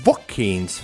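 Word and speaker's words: Joaquin: Žoakims Fēniks, es viņu tomēr par Žoakimu saucu jeb Vokīns Fēniks. Ar, arī tā. – Vokīns.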